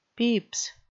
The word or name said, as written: peeps